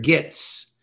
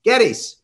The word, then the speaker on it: Gittes: And of course, the other thing that people will remember from the movie, those who've seen it, is that John Huston could not pronounce Gittes. – Right. – He pronounced it Gittes.